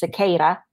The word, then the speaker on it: Sequeira: So my last name, Sequeira, is actually probably pronounced Sequeira, and it's a Portuguese last name.